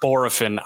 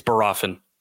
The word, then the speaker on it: Bohrofen: It's not Borofin. I think it's Barofin. – Barofin. B-O-H-R-O-F-E-N for those who are not following along.